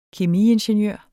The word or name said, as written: kemiingeniør